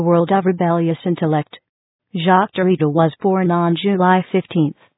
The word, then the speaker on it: Derrida: Even as a teenager, Mr. Derrida, the name is pronounced Derrida, was a voracious reader whose eclectic interests embrace the philosophers Jean-Jacques Rousseau and Friedrich Nietzsche, Albert Camus, and the poet Paul Valéry.